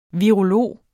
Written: virolog

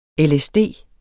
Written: lsd

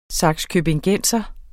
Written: sakskøbingenser

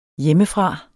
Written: hjemmefra

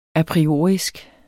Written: apriorisk

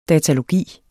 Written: datalogi

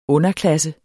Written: underklasse

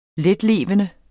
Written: letlevende

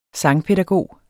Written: sangpædagog

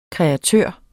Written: kreatør

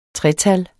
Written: tretal

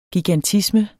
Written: gigantisme

